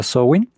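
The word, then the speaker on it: Samhain: Y bueno, em, como todas las buenas fiestas, creo yo, su origen es eh, pagano de la cultura celta, eh, conocida como Sowin, se pronuncia Sowin,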